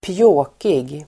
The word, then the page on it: pjåkig: pj'å:kig